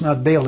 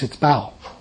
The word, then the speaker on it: Baal: In actuality, that word is not Baal, it's actually Baal. It's pronounced in the Hebrews, it's Baal.